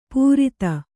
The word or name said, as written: ಪೂರಿತ